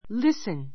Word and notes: listen: lísn; りスン

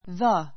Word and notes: the: 弱形 ðə; ざ; ði; ずィ; 強形 ðíː; ずィー

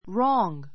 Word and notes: wrong: rɔ́ːŋ; ローンぐ; rɔ́ŋ; ロンぐ

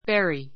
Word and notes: bury: béri; ベリ

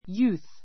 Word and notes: youth: júːθ; ユーす

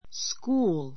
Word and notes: school: skúːl; スクーる

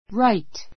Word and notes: right: ráit; ライト

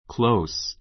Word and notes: close: klóus; クろウス

